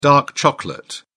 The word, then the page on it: dark chocolate: British English